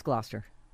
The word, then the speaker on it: Gloucester: Scallop. It's Gloucester. – Whatever. – I know it's spelled Gloucester. It's actually spelled like Gloucester. – It's Gloucester, right. That was my best guess at a bad pronunciation.